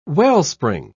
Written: wellspring

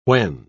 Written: when

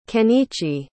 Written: ken'ichi

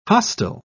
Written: hostile

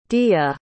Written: diya

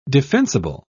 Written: defensible

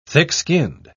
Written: thick-skinned

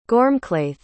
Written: gormghlaith

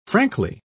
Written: frankly

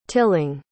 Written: tilling

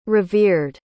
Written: revered

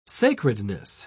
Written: sacredness

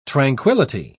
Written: tranquillity

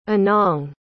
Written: anang

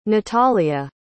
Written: natalia

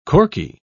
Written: Corky

